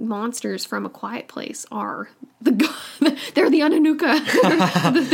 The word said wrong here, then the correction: Anunnaki: the council. I totally said that wrong. What's it called? How do you pronounce Anunnaki? – It? Anunnaki. All right, sorry. – That's how they pronounce it in Ancient Aliens, anyway.